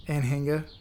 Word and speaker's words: Anhinga: Yeah, like an Aninga. – Anhinga. – Anhinga, yeah.